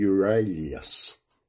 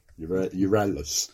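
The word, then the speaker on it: Euryalus: Well, he and his staff were aboard the Euralus. – Oh, bad effort. – Euralus. Which is situated just off W Beach.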